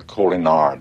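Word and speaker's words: Kolinahr: Yes, you were undergoing the Colonier discipline. – Sit down. – If you are referring to the Colinar, Doctor, you are correct.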